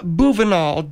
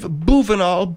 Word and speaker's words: Boovinulle: And his name is Dave Bouvenal. Dave Bouvenal, B-O-O-V-I-N-U-L-L-E, with the little French Bouvenal, only with, you know, that's not the French pronunciation, it's the English.